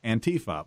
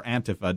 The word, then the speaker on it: Antifa: but of the problem presented by Antifa, or Antifa, depending on which pronunciation you go with.